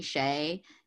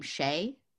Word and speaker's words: Sheh: I'm on Twitter and it's just my name at Stephanie Shea. If you want to follow me on Instagram, it the number 2 and my last name Shea. Shea is spelled S-H-E-H, but it's a pun.